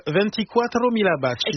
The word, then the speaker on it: 24 mila baci: Est-ce que je prononce bien Alba Pezzone, Adriano Celentano, 24 mila baci.